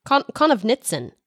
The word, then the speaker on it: Konovnitsyn: Yeah, one of the things that I noticed that was a comparison, I guess, between Konovitsyn and that's another syllable in there. Is that right? Konovitsyn? Konovnitsyn. There's an N.